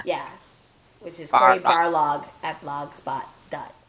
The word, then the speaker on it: Barlog: I'm still concerned because Corey Balrog, Barlog. I always say his name wrong because I think of Balrog from Street Fighter.